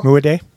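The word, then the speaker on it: Mudiay: Emmanuel Muadé.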